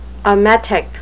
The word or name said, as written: emetic